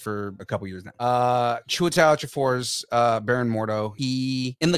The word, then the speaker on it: Chiwetel: Chiwetel.